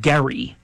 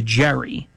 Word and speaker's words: Gerry: I mean, if you know about where gerrymandering came from, there was a governor by the name of Eldridge, and he pronounced it, I believe, Gary, G-E-R-R-Y, but it turned into Jerry, and then it became an action verb, gerrymandering. If you just flat out rigged a district to make sure that it would produce the result that you want.